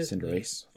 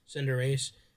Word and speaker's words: Cinderace: I will say Cinder, how do you say it? – Cinderace. – Cinderace.